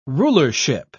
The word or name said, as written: rulership